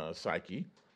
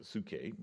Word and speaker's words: psyche: And then, of course, your soul, Greek word there, psyche, which some people pronounce it psuche, but these components are the mind or intellect and intellect, the will and emotions.